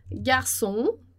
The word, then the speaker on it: garçon: E aqui a gente tem o on que vai aparecer na palavra garçon, né? Lembrando lá que a gente não vai pronunciar o S no final de palavra.